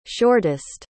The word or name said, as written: shortest